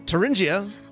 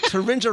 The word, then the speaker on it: Thuringia: You are Nancy, and I'm going to pronounce your name right, Thuringia. – Close enough? – Close. – How is it? – Yep, close enough. Thuringia, sort of like ninja. – Oh, that's even better. Thuringia rhymes with ninja.